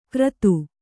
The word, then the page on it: ಕರ್ತು: kannada